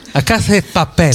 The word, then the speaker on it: La Casa de Papel: Ecco, come l'ho detto io prima? – Com'è? – La Casa de Papel. – Sì, ci siamo, secondo me. – Scusa, la pronuncia è esatta, c'è poco da fare.